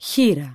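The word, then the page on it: χήρα: Greek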